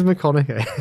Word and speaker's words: McConaughey: I would have Famous Matthew Mahoney. He could be the lead. – Mahoney? – Matthew Mahoney. – He means McConaughey.